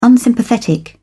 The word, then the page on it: unsympathetic: British English